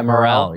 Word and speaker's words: Morril: we were to Zoom and it was I how do I say his last name? Sam Morril? Morril? – Oh yeah, Morril, yeah.